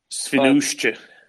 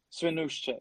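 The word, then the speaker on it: Świnoujście: The Finnish ship Alfia delivered 18,000 tons of Ukrainian corn to the Spanish port from the Polish port of Svinoujście. I don't know how to pronounce it, sorry. – Svinoujście. – Svinoujście, yeah, okay, that's better.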